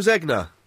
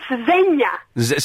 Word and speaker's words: Zegna: Oh, yes, I pronounced it Ermengildo Zegna. – It's Zegna.